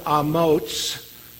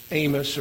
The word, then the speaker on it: Amoz: He was the son of Amoz, not Amos or Amos, but Amoz with the TS sound at the end, meaning strong or courageous.